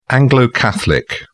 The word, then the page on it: anglo catholic: British English